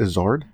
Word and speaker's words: Izzard: I think it's Izzard. – Izzard? – Yeah. It's a hard R.